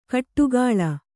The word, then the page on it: ಕಟ್ಟುಗಾಳ: kaṭṭugāḷa